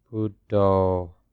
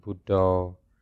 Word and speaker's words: Buddho: One of the important preliminary practices in the Thai forest tradition is to repeat the mantra Buddho, which is just the word Buddha with a slightly different pronunciation, Buddho, with the breath, Buddho. Bu with the inhalation, Do with the exhalation, silently, of course.